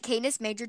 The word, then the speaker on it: Canis Major: But today we're doing the hopefully I'm saying this right, Canis Major Dwarf Galaxy.